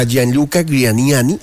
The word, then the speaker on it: Gianluca Grignani: Bueno, ahí hemos escuchado a Gianluca Grignani. ¿Sí se dice así bien? – Sí.